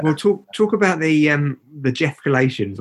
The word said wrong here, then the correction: Jeff Escalations: or Jeff, I'm saying it wrong, was it? – Jeff Escalations, yeah.